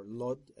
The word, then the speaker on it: Lod: I was born in a town called Elid, or Lod, as it's pronounced in Hebrew.